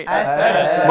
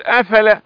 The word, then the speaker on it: أفلا: أفلا. – مرة ثانية كمان. – أفلا. – ممتاز ما شاء الله، لأن البعض يقول أفلا وهذا فعل خطأ، فلما أفلا قال لا أحب الآفلين.